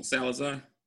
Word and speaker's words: Salazar: Roberto Salazar.